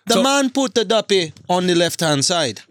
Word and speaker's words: Duppy: How do you pronounce it? – Kanye. – Duffy? – Duppy. Duppy.